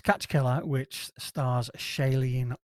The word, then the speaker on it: Shailene: And it's Shailee Woodley. Right. She was in the Divergent films. – See how Siri wants to say it. – Shailene Woodley. – Shailene.